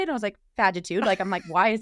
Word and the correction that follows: fatigue: And she goes, just so you know, it's actually like fatigue.